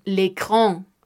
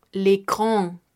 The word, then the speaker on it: écran: Notemos con la AN, por ejemplo, l'écran, l'écran, que es la pantalla.